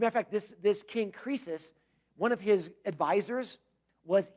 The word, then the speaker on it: Croesus: Now, it's spelled C-R-O-E-U-S or something like that, but it's Croesus.